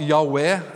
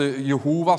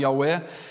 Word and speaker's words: Yahweh: Som man sier da på hebraisk Yahweh, altså Jehova, som noen sier. Det er heller en dårlig, dårlig uttale, men Yahweh.